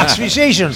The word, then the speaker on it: Asphyxiation: Un trabajo que esperábamos con ansia y que ya podemos disfrutar con temas poderosos, muy poderosos, como este Asphyxiation. O dilo tú, ¿cómo se...? – No, no, lo has dicho bien. – Joder, qué maravilla. Asphyxiation. – Se dice así.